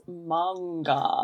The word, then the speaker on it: manga: It's manga. – Manga. – Manga. It's three syllables.